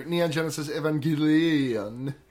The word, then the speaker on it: Neon Genesis Evangelion: Like, no mention of, like, you know, your Akira, your Cowboy Bebop, your Neon Genesis Evangelion. – Right. – That's how that's pronounced. – Yeah, that's exactly how it's pronounced.